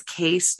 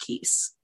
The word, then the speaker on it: Keese: I did touch base with a Dutch family member, and it is Case, not Keese. – Oh, really? Girardi, yes. Case? – It is Girardi Case. – Like a case of wine? – Yes. – Oh, okay. – It is pronounced Case.